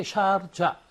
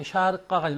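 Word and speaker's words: Sharjah: 근데 이 지역은 그 이란의 영향을 많이 받아서 발음도 이렇게 샤르자 이렇게 샤르카가 아니라 샤르자 이렇게 발음해갖고 영어 발음은 그대로 또 우리말로 그냥 옮겨와서 샤르자 합니다.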